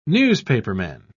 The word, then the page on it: newspaperman: British English